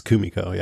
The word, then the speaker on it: Kumiko: I think it's Kumiko, yeah, with the emphasis on the first syllable.